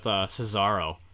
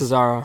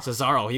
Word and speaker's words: Cesaro: Like last Raw with Cesaro. I still don't know how to pronounce his name. But like he's Cesaro. – Cesaro, he had an amazing match with him.